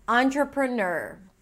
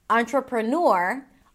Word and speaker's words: entrepreneur: You can either say nor. I've heard people saying nur with a y sound. And if you want to make it super easy, just say ner, entrepreneur. So entrepreneur, entrepreneur. All three options are valid. Use the one that is easiest for you.